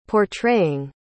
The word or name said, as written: portraying